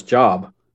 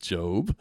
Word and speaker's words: Job: It says Job. – It's pronounced Job.